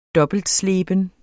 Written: dobbeltsleben

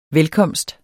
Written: velkomst

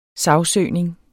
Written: sagsøgning

